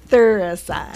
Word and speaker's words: Thuricide: Say it with me. – Bacillus thuricide. – Thuricide. There you go.